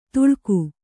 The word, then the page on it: ತುಳ್ಕು: Kannada